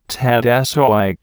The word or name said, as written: tat soi